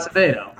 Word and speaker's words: Acevedo: How do you say his last name? I butchered it. Acevedo.